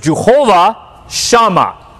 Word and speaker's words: Jehovah-Shammah: So the actual Hebrew, as you can see here, this is the Hebrew, the way you pronounce it is Jehovah-Shammah. So the accent's on the first part, Shammah, Shammah. And what this means is the Lord is there.